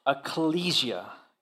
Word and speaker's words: ecclesia: The word church itself derives from the word ecclesia. Did I say that right, Pastor Ben? Ecclesia. And if you know Spanish, you're like, oh, that sounds just like iglesia. Yeah, you're not wrong.